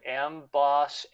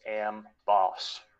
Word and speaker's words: Amboss: I was calling Amboss Ambos, but it is pronounced Amboss.